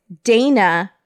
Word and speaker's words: Dayna: So Bill has two grown kids, Dana, but it's spelled D A Y N A.